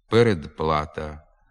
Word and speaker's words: передплата: У префіксах і прийменниках «об», «від», «над», «перед», «під», «між» звуки «б», «д», «ж» перед глухими приголосними зберігають свою дзвінкість. Наприклад, «обставини», «обслуговування», «відправити», «відпустка», «відтовариша», «надпити», «надкручею», «передплата», «перед труднощами», «підписати», «під пахвою», «міжпланетний», «між сусідами».